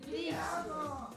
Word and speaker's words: ti amo: Te amo. – Ti, ti, ti. – Ti amo. – Ti, como si tiras así.